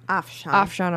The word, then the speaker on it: Afshan: And then they had Chris Rankin, Afshan. Did I say that right? – Afshan. – I'm so sorry.